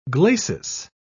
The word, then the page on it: glacis: British English